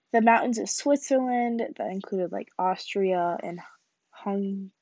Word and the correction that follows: Hungary: it's pronounced Hungary, shush, I don't know what you're saying, Italy, France, and Spain.